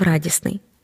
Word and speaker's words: радісний: Звернімо увагу на речення після кави ранок зразу став радісний. Радісний, але радість звук Т випадає, щоб не було трьох приголосних поруч.